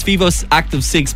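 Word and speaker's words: Vívoactive 6: You keep saying Vivo 6 Active, which I don't care because it's kind of the same, even though it's Vivo Active 6.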